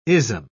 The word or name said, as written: ism